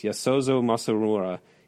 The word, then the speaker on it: Yasuzo Masumura: I asked a friend how to pronounce these things who speaks Japanese. Yasuzo Masumura.